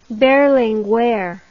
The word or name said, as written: Berlinguer